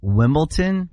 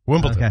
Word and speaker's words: Wimbledon: Wimbledon? – Wimbledon? – Wimbledon. – Wimbledon. – Wimbledon. – Yeah, I got corrected. – Wimbledon? – It's D-O-N. – Like down to N.